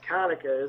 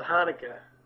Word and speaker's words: Hanukkah: I don't believe it's pronounced Kanukkah, is it? That sounds like a gas station. – Oh, I don't know. Is it? – Yeah, I thought it was Hanukkah.